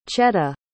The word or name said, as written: cheta